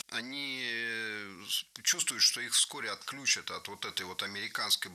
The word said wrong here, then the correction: отключат: Отключат, неверно, правильно, отключат. Что интересно, в глаголах с корнем ключ. Ударение на этот самый корень никогда не падает.